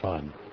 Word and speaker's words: fen: In Tibet, the kwai is divided into 10 mao, called motse, and the mao is divided into 10 fen, pronounced fun.